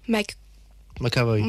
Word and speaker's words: McAvoy: McAvoy. – McAvoy, ok. – McAvoy.